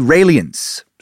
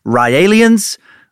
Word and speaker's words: Raelians: There aren't many religions or cults, whatever you end up calling these believers on this tiny blue ball in space of ours, like the Raelians, sometimes pronounced Ryalians. I'm going to go with Raelians because it's easier to say.